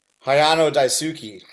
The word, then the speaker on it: Hayano Daisuke: So you do that, but then it seems like fairly soon after moving back, you also end up in, correct me on the pronunciation, is it Hayano Daisuke? – Hayano Daisuke.